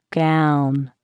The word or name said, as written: gown